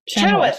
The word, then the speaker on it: Chenoweth: I don't know if I'm saying her name right. – Chenoweth? – Chenoweth, thank you.